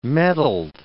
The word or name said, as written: mettled